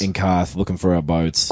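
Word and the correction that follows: Qarth: Quarth, I believe it's pronounced.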